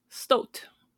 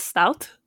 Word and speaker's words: stoat: And they're a stoat. I've been told yesterday that apparently I was pronouncing it wrong, and it is not stout, like like the little teapot.